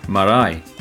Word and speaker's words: marae: Marae, Māori community meeting house.